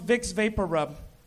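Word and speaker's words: Vicks VapoRub: After I moved here to realize that Vic Vaporru is actually pronounced Vic's Vapor Rub.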